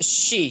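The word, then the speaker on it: she (四): Because in Japan, I mean, in Japanese character, four is pronounced she, yes, which is also the same word as death.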